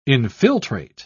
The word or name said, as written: infiltrate